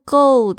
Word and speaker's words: goal: A long-term goal，这个 goal 大家特别注意一下你们的 l 的发音哦。就是如果只有 go，然后舌头没有动作的话呢，就会变成 go 这个 go go go 加油加油这个这个字哦。